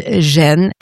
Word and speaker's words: Jeanne: Well, I think it, first of all, Joan of Arc was, her name was Jeanne, pronounced and written in the old French, I was going to say old English, the old French way. Her family name was Arc, was Dark, D-A-R-C.